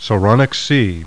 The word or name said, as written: SARONIC